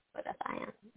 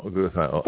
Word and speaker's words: glutathione: How do you spell it? – G-L-U-T-A-T-H-I-O-N? I-O-N-E. – Glutathione. – Yeah. – Oh, glutathione. – So it's better to get your glutathione from a supplementation or from a patch, really.